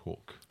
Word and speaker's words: Hooq: H-O-O-Q. – But it's pronounced Hook.